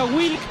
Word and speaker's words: Wlk: El apellido se escribe WLK, pero se pronuncia Wilk, de origen austríaco.